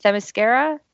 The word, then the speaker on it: Themyscira: how do you pronounce the name of the island? Themyscira? – That sounds correct to me.